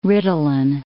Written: Ritalin

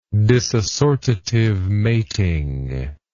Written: disassortative mating

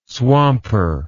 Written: swamper

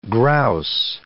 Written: grouse

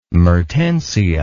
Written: Mertensia

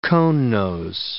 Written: conenose

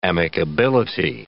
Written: amicability

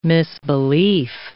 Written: misbelief

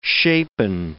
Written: shapen

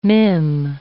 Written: mim

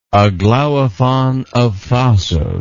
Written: Aglaophon of Thasos

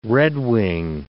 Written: redwing

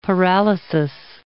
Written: paralysis

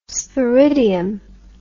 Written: sporidium

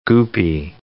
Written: goopy

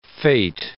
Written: fete

